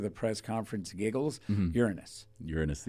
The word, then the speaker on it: Uranus: These lips come up and you hit the thing and it says straight up Uranus. – Wow. Uranus. That's how the Google people say it.